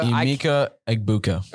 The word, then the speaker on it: Emeka Egbuka: as well as Mbaka, or however you pronounce his name. I keep literally messing it up every time. Mbuka or Emeka Egbuka. – Emeka Egbuka. – Oh, yeah, that's how you make it.